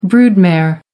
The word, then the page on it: brood mare: American English